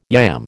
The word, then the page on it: yam: American English